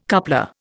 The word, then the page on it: coupler: Indian